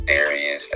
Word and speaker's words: Darius: My social media, my Facebook is Darius, that's D-A-R-I-U-S, Juzt Duke, and Juzt Duke is spelled J-U-Z-T-D-U-K-E, not J-U-S-T.